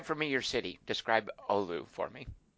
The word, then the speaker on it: Oulu: Oulu.